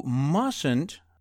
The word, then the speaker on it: mustn't: You mustn't. By the way, we do not pronounce the first T, M-U-S-T-N-T, mustn't.